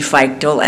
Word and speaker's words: Feichtl: My name is Nancy Feichtel, and it's spelled F-E-I-C-H-T-L, believe it or not.